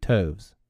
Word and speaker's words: toves: How to pronounce Scythe Toves? The I in Scythe is long, as in writhe, and Toves is pronounced as to rhyme with groves.